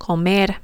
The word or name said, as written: comer